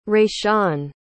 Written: Rayshawn

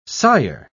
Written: sire

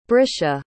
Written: brisha

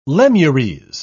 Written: lemures

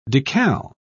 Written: decal

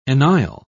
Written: enisle

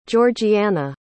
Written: georgiana

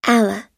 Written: allah

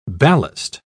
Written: ballast

